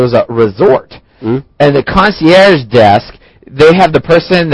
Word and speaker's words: concierge: Concierge. C-O-N-C-I-E-R-G-E. Concierge. – 下一个单词 concierge. Well, this is a French word.